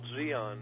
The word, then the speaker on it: Dian: Well, the base camp was called Dian, D-I-A-N, but uh-huh. the Vietnamese D is pronounced with like Z.